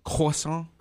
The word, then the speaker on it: croissant: You'll hear it the French way where they get the back of their mouth, they go croissant like that, and then you'll get the English way where it's just croissant, you know. – I like the way you said that the first time. Say that again. – Croissant. That's how they say it in France. It's a French food.